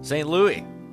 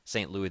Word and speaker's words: St. Louis: St. Louis, pray for us. You know, in Cardinals country, they pronounce the S.